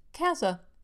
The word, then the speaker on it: Kasza: Kaza, you say? Is it pronounced? Kaza, are you doing this correctly? Yes, as it turns out. While Kaza may look like it should be Japanese, in fact, it's her husband's Hungarian family name and is pronounced Kaza.